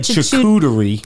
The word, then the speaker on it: charcuterie: Chuchuchery. – We're saying it wrong. – I don't think we are. Just because he said charcuterie.